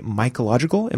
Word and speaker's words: Mycological: So you are a member in more ways than one with the Mycological. Am I saying that correctly? – Yes, you are.